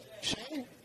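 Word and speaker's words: Hsieh: How do you pronounce his name? – Shay.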